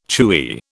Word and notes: chewy: British English

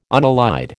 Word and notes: unallied: American English

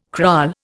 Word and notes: kraal: Indian

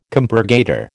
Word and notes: compurgator: American English

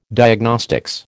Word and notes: Diagnostics: American English

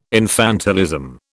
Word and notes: infantilism: British English

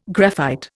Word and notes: graphite: Indian

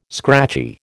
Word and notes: scratchy: American English

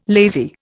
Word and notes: lazy: Indian

